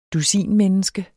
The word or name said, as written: dusin-